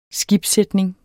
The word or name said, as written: skibssætning